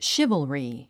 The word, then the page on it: chivalry: ʃívəlri; シバァリィ